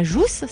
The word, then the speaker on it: jasse: Et récemment, il y a eu la restauration de la jasse de Combes, la Jousse, si je le dis bien. C'est bien ça ? – C'est ça, c'est tout à fait ça, oui.